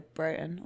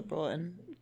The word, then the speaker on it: Broughton: And I do the Broughton or the Broughton. God knows there's contention about how to say it. – Broughton. – Broughton. – The Broughton is what I hear from the Edinburgh locals.